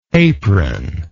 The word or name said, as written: apronlike